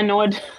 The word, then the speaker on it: Feyenoord: Feyenoord, or I don't know how to pronounce it. – Yeah, Feyenoord.